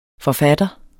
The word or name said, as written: forfatter